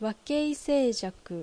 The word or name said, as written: Wakeisei